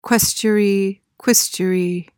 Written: quaestuary

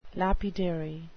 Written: lapidary

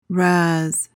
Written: razz

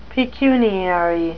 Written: pecuniary

